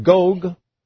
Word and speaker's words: Gog: We have Gog. That's a long O, y'all.